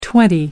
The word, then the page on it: twenty: American English